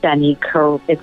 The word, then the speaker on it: Carrieri: Jenny Carrieri.